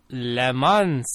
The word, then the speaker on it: Le Mans: The S is silent. – Which one is? – The S in Le Mans. – Oh. – It's a Frenchers. – It's a fancy French word.